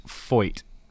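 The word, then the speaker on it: Feucht: And I believe it's pronounced Foyt. Sean Foyt.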